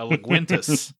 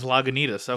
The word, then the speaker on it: Lagunitas: I had to remind Ashley of that story, but every time I have a Lagunitas, I remember John pronouncing it as Laguintas. Everyone on the call being like, what the fuck is that? And then eventually realizing, oh, he means Lagunitas.